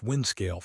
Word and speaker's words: Windscale: The 1957 Windscale, pronounced Windscale Fire, is the definitive example of prioritizing political optics over safety.